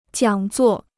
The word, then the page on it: 讲座: jiǎng zuò